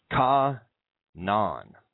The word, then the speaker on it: Canaan: It's spelled C-A-N-A-A-N. Ka-Nan.